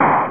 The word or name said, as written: eraser